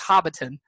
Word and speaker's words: Hobbiton: It's pronounced Hobbiton. It's where the hobbits. It's where Bilbo Baggins lives in The Hobbit, and it's where Frodo is from in The Lord of the Rings.